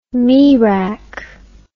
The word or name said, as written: merak